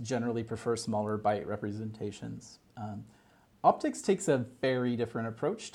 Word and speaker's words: OptiCSS: So optics, despite the fact that its name makes you think it should be pronounced as opti CSS,